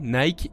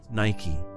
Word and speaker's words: Nike: En anglais, Nike et Niké se prononcent de la même façon. – Nike.